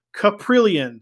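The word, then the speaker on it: Kaprielian: I got the pronunciation guide. It is James Caprillion.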